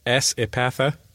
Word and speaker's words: S. Epatha: If it has to be a woman's name, can I suggest S. Epatha? – Yes. You know that's pronounced Sepatha, don't you? – No, what? – It is. It really and truly is.